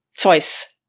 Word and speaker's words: Zeus: Whereas with SUS, uh, Zeus, how, how do you say SUS? Zeus. – The wallet. Yeah, I say Zeus, yeah.